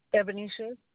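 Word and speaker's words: Ebonisha: Ebenisha.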